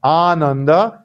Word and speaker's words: Ananda: For example, Anuttara starts with a simple A, but Ananda starts with a long A that's corresponding to the second.